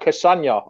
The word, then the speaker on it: Castagne: It's like lasagna, Castagna.